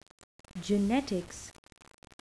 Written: genetics

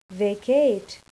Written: vacate

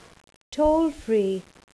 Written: toll free

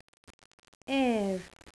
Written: heir

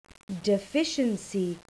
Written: deficiency

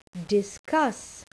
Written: discuss

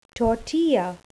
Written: tortilla

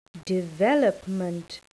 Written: development

